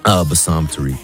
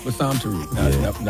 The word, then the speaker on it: Bassam Tariq: How do you pronounce the director's name? – Bassam Tariq. – Bassam Tariq.